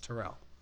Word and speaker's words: Terrell: They weren't saying Terrell. – Terrell? – Yeah. They kept calling him Terrell. It bothered me. I'm like, no, it's Terrell. It's Terrell Owens.